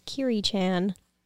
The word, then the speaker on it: Kyuri-chan: And my at name is Kyuri-chan.